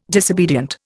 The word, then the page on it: disobedient: Indian